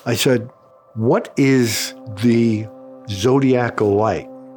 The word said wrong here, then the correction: zodiacal: He says, the zodiacal light. I said, oh, I'm mispronouncing it. He said, yeah, that's fragments that come off the sun.